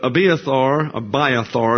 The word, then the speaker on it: Abiathar: But Abiathar is the way it's pronounced, was the son of Ahimelech.